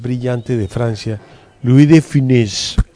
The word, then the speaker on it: Funès: Yo decía Louis de Funès. – Es exactamente como usted lo dice, pero la U, Funès. – Así como F. – Funès. – Porque la E tiene acento invertido, por lo cual es, si no tuviera acento sería Funès.